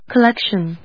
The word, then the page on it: collection: col・lec・tion; kəlékʃən